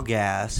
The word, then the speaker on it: Gass: Kyle Gass, whose last name rhymes with ass.